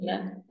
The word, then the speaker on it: Signe: Is it Sinya? Is that how you say it? Sinya, yeah. Yeah.